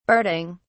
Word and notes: barding: British English

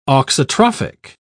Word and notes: auxotrophic: British English

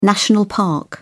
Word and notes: national park: British English

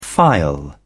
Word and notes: phial: British English

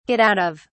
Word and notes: get out of: British English

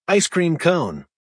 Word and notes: ice cream cone: American English

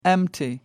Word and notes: empty: American English

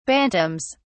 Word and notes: bantams: British English

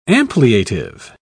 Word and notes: ampliative: British English

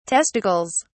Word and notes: testicles: British English

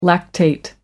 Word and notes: lactate: American English

